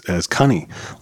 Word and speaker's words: Coney: But Coney, actually, and I'm actually mispronouncing it wrong, because they actually used to pronounce it as cunny, like honey. – Okay. – But it's C-O-N-E-Y. And so, I think it's the American in us that, that's why we still call it Coney Island instead of Cunny Island or whatever.